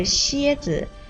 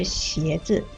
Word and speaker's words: 鞋子: 志敏，刚才听你说鞋子的发音有一点不太准确，你说的是蝎子，一声，但是应该是鞋子，鞋是二声。